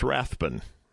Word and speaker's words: Rathbun: Now, what was interesting to me is, for those of you that know who I am, my last name is Rathbun, R-A-T-H-B-U-N.